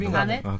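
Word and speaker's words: Kevin Garnett: 네, 아무튼 우리 그 케빈 뭐라고 읽는 거죠? 케빈 가넷.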